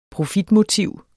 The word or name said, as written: profitmotiv